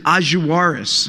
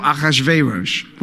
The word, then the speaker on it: Ahasuerus: and they said Ahasuerus. And I thought, Ahasuerus, who is that? Because we always pronounce him Achashverosh.